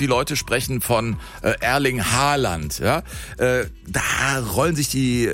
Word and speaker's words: Haaland: Er wird ausgesprochen Haaland, also mit O.